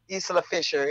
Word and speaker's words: Isla Fisher: How do you say Isla Fisher? – Isla Fisher. – Yeah. Isla Fisher. Is that how you say her name? – That's how I say it.